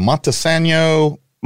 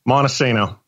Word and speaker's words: Montesano: How do you say the name of the town you're from? Montesano, Montesano. Montesano, like insano. Montesano.